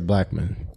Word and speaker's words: Blackmon: Blackmon.